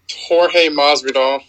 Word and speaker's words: Jorge Masvidal: How do you pronounce it? – Jorge Masvidal. – Jorge Masvidal? – Yes.